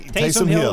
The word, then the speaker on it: Taysom Hill: Taysom Hill.